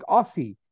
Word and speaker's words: Aussie: Because it's spelled A-U-S-S-I-E. So you think Aussie. But we pronounce it O-Z-Z-I-E, Aussie.